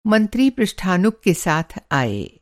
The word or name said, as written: पृष्ठानुग